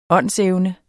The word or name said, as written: åndsevne